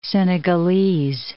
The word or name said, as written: Senegalese